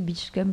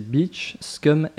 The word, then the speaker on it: Beach Scum: alors rappelle-nous comment ça s'écrit Beach Scum,